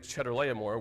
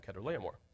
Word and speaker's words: Chedorlaomer: Now I was reading it, and it's in English we pronounce the CH with a ch sound, so well, you want to read it Chedorlaomer, which just made me think of cheese, so I just stuck with the proper pronunciation and called him Chedorlaomer.